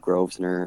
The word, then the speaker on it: Grosvenor: They threw an M in there, and I was like, well, it has an S in it, so it's a silent S. And everyone usually pronounces it Grosvenor, and understandable, doesn't bother me much.